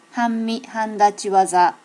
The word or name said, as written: hanmihandachiwaza